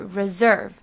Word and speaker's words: reserve: 类似的发音还有像是 reserve, 它们的 S 呢，都并不是发传统的 S 的音，而是发 z 的音，所以念成是 resort 或者是 reserve。